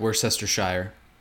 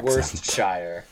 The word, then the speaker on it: Worcestershire: I expected more like Worcestershire. – Do you hurt inside Sean when he pronounces it like that? – Yes. I hurt inside every time I hear an American accent. – Worcestershire.